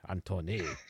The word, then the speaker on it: Antoine: And Anton, yeah. Anton Béchamp. Yeah. – But we need to sound fancy because he was French. Antoine. – Oh, well. – No, that sounds Antoine, Italian.